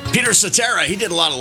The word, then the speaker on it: Cetera: Peter Cetera's brother, Ed, pronounces his last name slightly different, although it's spelled the same way, Ed Cetera.